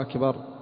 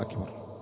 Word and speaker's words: أكبر: أما أن نقول لو أن شخصا عاميا قال الله أكبر، قل له أخطأت، قل الله أكبر، لأنه لا يقصد الاستفهام، إنما هي لفظة أخطأ في نطقها، أخطأ في نطقها.